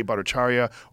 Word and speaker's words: Bhattacharya: Jay Bhattacharya. Jay Bhattacharya, or that gentleman from the UK, John Campbell, or some of these other doctors.